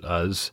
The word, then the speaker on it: Uz: Job was from the land of Uz or Uz. I pronounce it Uz.